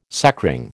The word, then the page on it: sacring: American English